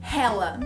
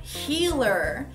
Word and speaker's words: healer: Por exemplo, hella. O que, é hella? Ou hila. What is that? Hila, na verdade, é a palavra healer.